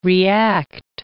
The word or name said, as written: react